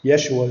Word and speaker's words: Yeshua: Yeshua is how the Jews would pronounce it.